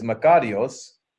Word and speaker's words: makarios: The Greek word is makarios, blessed or blessed. I got to spell that. – Yes, all right. M-A-K-A-R-I-O-S. I-O-S. Makarios.